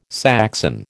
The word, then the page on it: Saxon: American English